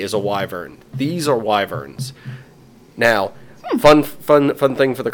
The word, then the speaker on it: wyverns: And that is, they're technically wyverns.